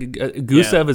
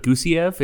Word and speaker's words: Gusev: It was like Gusev is actually Gusiev.